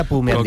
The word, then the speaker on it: Bu: Yun Chaoquete Bu. – Se pronuncia Pu. – Como Chanquete, ¿no? – No tiene nada que ver con Chanquete.